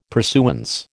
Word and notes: pursuance: American English

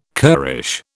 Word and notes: currish: British English

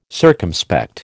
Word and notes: circumspect: American English

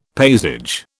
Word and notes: paysage: British English

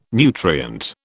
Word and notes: nutrient: British English

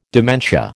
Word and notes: dementia: American English

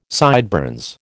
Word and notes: sideburns: American English

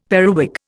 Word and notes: periwig: Indian

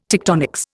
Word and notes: tectonics: Indian

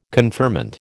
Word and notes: conferment: American English

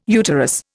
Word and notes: uterus: Indian

